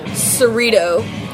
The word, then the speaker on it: Cerrito: It's 19729 Cerrito, Cerrito. It rhymes with burrito.